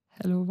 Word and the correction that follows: Halo: Äh, Hallo geschrieben. Die Xbox Hallo. Halo, wie spricht man es aus? – Halo. Halo, Entschuldigung. Hey, ah, ja, ich die ist schon rausgekommen.